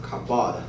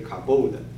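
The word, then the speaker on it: kabod (כבוד): 在旧约圣经里边，荣耀那个词呢，基本上是一个词，它有的时候会有两种形式，那个它的读音呢比较像 kabod 或者是 kabod。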